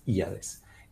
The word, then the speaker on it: Híades: Se llama Híades, se escribe con H, H-I-A-D-E-S, Híades.